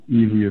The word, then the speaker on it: Ely: Ely. Ely is spelled E-L-Y.